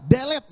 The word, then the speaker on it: delete: Ini apa? Delete ya? – Delete? Yang benar adalah delete.